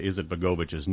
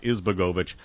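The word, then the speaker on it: Izetbegović: Aliyah is, by the way, I mispronounced Aliyah Izetbegovic's name. I left out a syllable. It's Izetbegovic, not Izbegovic.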